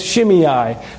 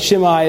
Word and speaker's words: Shimei: Shimei, sometimes described by me as Shimei. I may get the pronunciations twisted, but Shimei is a better pronunciation of his name.